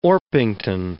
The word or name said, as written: Orpington